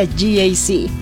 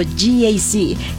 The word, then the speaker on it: GAC: Se escribe GAC, pero se pronuncia GAC. Repítelo, GAC, que el de al lado te escuche.